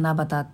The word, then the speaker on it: 七夕: 漢数字の 7 に夕方の夕で、まあ、七夕って読む